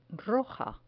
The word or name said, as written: roja